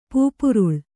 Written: ಪೂಪುರುಳ್

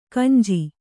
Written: ಕೊಂಜು

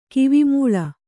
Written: ಕಿವಿಮೂಳಿ

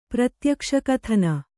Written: ಪ್ರತ್ಯಕ್ಷಕಥನ

